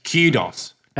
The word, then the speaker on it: kudos: It's so, apparently kudos. Okay, kudos like do, do, you want to do you want like real nerding out here? – Yes.